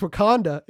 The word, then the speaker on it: Wakanda: And he kept saying Wakandu. And eventually he came up to me afterwards. He's like, what'd you think of that sermon illustration? I go, I'll be honest, man. It's pronounced Wakanda.